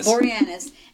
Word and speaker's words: Boreanaz: I just thought it was David Boreanaz, but I can't say his name. – Boreanaz.